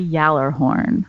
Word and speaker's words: Gjallarhorn: Well, how is that supposed to be pronounced? – I say Yallerhorn. – Okay, well, that's wrong. – But that's clearly not how Bungie decided to pronounce it, even though that's how they spelled it.